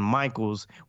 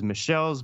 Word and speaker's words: Michels: Michels with his own respective band, El Michels Affair. Now, Leon Michels, not to be mispronounced as Michelle's because of how his last name is spelled, mm.